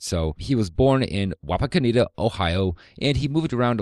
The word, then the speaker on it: Wapakoneta: And I looked up the pronunciation. It is Wapakoneta.